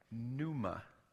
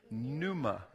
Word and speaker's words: pneuma: The new word is pneuma. Can you say that? Pneuma. We're not going to spell it because it's spelled really weird. But the word is pneuma.